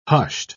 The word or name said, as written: hushed